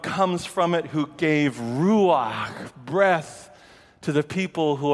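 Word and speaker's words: Ruach (רוח): The word is ruach. Say that with me, please. Ruach. The second syllable, put it in the back of your throat. It's kind of a kh.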